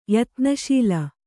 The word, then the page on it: ಯತ್ನಶೀಲ: Kannada